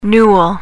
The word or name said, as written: newel